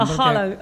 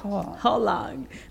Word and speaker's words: Haaland: A Haaland, Erling Haaland. – Sí, aprendí sí, sí que no se dice Holland.